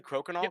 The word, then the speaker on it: crokinole: Even when I was starting to type it into Google, it was like crokinole. Is am I pronouncing that correctly? Crokinole. – Yep.